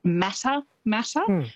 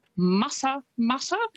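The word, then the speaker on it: Matamata: Quite often, because of how it's spelt, people will pronounce it Mata Mata, but it's Mata Mata.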